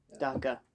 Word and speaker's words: DACA: DACA.